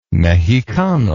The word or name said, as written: Mexicano